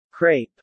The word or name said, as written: crepe